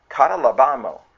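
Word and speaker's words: catalabano (καταλαμβάνω): That comprehending word, it's spelled K-A-T-A-L-A-B-A-N-O. And it pronounces like catalabano, catalabano, catalabano.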